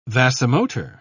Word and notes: vasomotor: British English

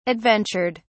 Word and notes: adventured: British English

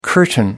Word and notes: curtain: American English

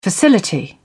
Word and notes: facility: British English

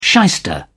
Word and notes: shyster: British English

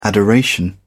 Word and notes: adoration: British English

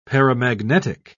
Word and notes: paramagnetic: British English

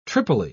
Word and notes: tripoli: British English

